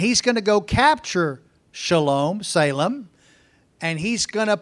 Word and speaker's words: Shalom: Now, in the Hebrew, it is Shalom. Anytime you hear the word Salem, it is Shalom. That's the Hebrew, how it's said in Hebrew.